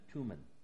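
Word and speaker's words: Thummim: But it also could have been done by the priest using the, I want to pronounce this correctly because a lot of times it's pronounced Urim and Thurim, but it's Urim and Tumim, to determine the Lord's will.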